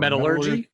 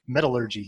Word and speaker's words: metallurgy: How do you say that word? – Metallurgy? – Metallurgy. Yes. Thank you.